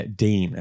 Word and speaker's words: Digne: Dean, as most people pronounce it, but Miles likes to say it.